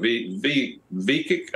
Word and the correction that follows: Vivek: And he says, it's Vivek. And a lot of people say it the way you just said it, but it's Vivek, and I got that from him.